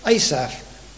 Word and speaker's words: Asaph: the word for take away is Asaph. ASAPH, as we would say in the English and spell it in English.